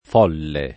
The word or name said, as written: folle